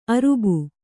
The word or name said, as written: ಅರಬು